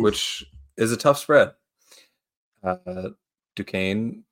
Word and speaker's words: Duquesne: I believe Duquesne. That's how you say it.